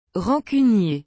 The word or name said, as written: rancunier